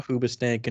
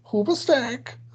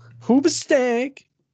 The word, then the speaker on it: Hoobastank: You know, I almost saw Hoobastank in Orlando. – You're not saying it right. It's Hoobastank. – Hoobastank.